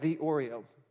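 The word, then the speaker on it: theoreo (θεωρέω): It's actually spelled like the Oreo, if you want to write it down. The Oreo, but it's theoreo.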